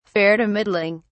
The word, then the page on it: fair to middling: British English